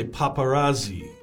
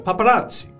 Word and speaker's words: paparazzi: Like a lot of people might say paparazzi. – 对。 – But uh, paparazzi.